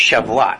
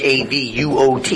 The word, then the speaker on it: Shavuot: And they pronounce it, this feast is pronounced Shavuot. I don't know how they pronounce it. It's Shavuot.